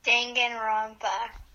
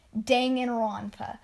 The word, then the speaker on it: Danganronpa: Danganronpa. – There you go, everyone. Danganronpa.